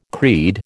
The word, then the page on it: creed: American English